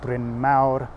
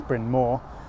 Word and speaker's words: Bryn Mawr: You have Welsh place names like Bethesda, Balacunwyd, Bryn Mawr, which as a college the Americans pronounce Bryn Mawr,